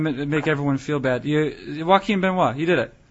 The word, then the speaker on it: Joaquin Benoit: I mean, what they've got right now in Detroit, I mean, you've got Valverde dealing with a wrist issue, and Joaquin Benoit, if I'm saying his name correctly. – Yeah, you're doing it. You're doing it. – I don't want to say Joachim Benoit.